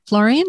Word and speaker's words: Florian: Firstly, hello, and thank you for your time today, Florian. Is it Florian? Is that how you pronounce it? – Yes.